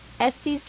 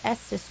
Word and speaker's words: Estes: We hit the streets to find out how you say it. Estes Drive or Estes Drive? – I live in Chapel Hill. I say Estes Drive. – I live in Chapel Hill, and it's pronounced Estes.